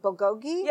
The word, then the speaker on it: bulgogi: I understand that on your menu, you have a dish, and I never say it right, bulgogi? – Yes. – I said it right.